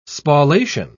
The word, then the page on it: spallation: British English